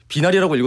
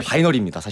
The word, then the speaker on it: 바이너리: 비나리라고 읽어도 사실은 너무 감사하고 바이너리라고 읽어도 좋다. 약간 그래서 이것도 언어적 유희처럼 이렇게 왔다 갔다 하는. – 예, 예, 예. – 네. 하셔도 돼서. – 그러니까 근데 바이너리입니다, 사실은.